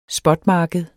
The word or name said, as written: spotmarked